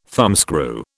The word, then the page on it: thumbscrew: British English